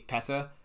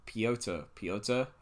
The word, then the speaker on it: Piotr: I keep saying an American accent because I keep saying Petr, like Petr Cech, but because all the Americans say Piotr, I have to say it like that. Piotr, yeah.